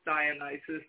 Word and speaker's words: Dionysus: The way that I use it the most is Dionysus, but there's different Greek into English spellings, and sometimes it's S-O-S. And as you work with Dionysus, that's the pronunciation I use.